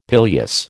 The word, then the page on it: pileus: American English